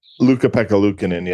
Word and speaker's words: Ukko-Pekka Luukkonen: I mean, I can't pronounce the kid's name, the big Finn that seems to Ukko-Pekka Luukkonen, yeah.